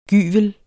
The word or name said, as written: gyvel